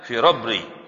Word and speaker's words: Firabri: Wan Nasa'i, Imam An-Nasa'i, wa Tirmidzi, Imam At-Tirmidzi, wal Farabri atau Firabri, nah bacanya Firabri.